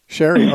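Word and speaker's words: Cheri: Let's talk to Chari from Michigan. Welcome to The Narrow Path. Thanks for calling. – Hi, Steve. It's Sherry. – Sherry. Okay. It's spelled wrong here. – No, it's C-H-E-R-I.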